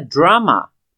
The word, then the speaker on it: drama: Essa matéria drama, para nós seria aula de teatro. D-R-A-M-A. Drama. Vamos colocar assim, na pronúncia, a sílaba tônica dela é a primeira.